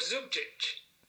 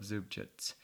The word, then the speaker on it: Zubčić: Jimmy, it's at the end, it's also a CH sound. – Oh, it is. Okay. – Yeah, you know, this is apparently Croatian. That when you with that little accent on the last C, mm-hmm. turns it into a ch sound. – Okay. – So both the C's in Zubčić are sounded the ch.